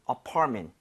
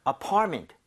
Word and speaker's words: apartment: So 강세를 제대로 주면 MENT가 그렇게 발음이 돼요. 여기 강세 주고 한번 해보면 네? – apartment보다는 apartment. Apartment. 그래요? – Apartment.